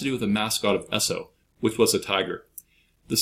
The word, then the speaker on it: Esso: Jersey Standard marketed its products under the brand Esso, which is the phonetic pronunciation of the initials S and O in Standard Oil.